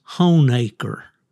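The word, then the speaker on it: Honaker: If you are a native of Southwest Virginia, that is Honecker.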